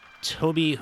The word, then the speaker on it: Tobe: So this week we're talking two parts about director, filmmaker, writer Tobe Hooper. And it is pronounced Tobe. I've been saying Tobe Hooper my whole life.